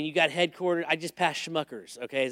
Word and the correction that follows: Smuckers: Is that how you say it, Schmuckers? Smuckers. I just passed Smuckers in Orwellville.